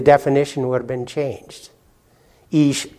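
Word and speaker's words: ish (אִישׁ): In the Hebrew, you, you would pronounce it ish. Ish. That's the Hebrew for man.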